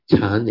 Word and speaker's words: Chan: Chan is the Mandarin pronunciation for Zen.